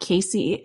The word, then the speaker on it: Caissie: And you can also find me on Twitter, just at my first name, at Caissie. It's spelled like Cassie with an extra I in there.